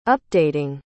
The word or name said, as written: updating